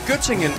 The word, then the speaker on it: Göttingen: Var det ved Gødringen? – Nej, Göttingen.